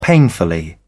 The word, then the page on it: painfully: British English